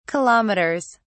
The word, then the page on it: kilometers: British English